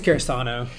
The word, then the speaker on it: Karasuno: I think it's Karasuno. Karasuno, sorry.